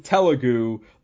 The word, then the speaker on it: Telugu: I think it's Telugu. There you go. It's Telugu. – I think that's what it is.